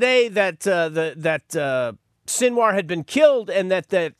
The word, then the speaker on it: Sinwar: Yahya is his first name, Y-A-H-Y-A, Yahya. His last name is spelled S-I-N, Sin, W-A-R, War.